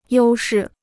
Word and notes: 优势: yōu shì

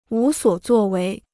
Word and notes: 无所作为: wú suǒ zuò wéi

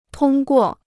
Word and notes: 通过: tōng guò

